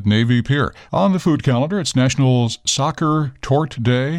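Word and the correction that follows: Sachertorte: I'm probably saying that wrong. – Zacha Tort. – Okay, well, it's celebrating the chocolatey dessert that was created in Vienna in the 1800s. – Zacha Tort.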